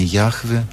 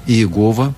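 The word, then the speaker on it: Яхве (יהוה): Современные библеисты считают наиболее близкой к исходной форме произношения Яхве, более распространенно и, к сожалению, ассоциируется теперь с небезызвестной сектой, это Иегова.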